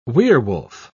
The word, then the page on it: werwolf: British English